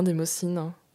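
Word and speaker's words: démoscène: Alors, est-ce que je prononce bien démocine C'est parfait.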